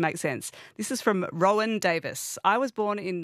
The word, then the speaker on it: Roen: Now, that's spelled R-O-E-N. They spelt it phonetically.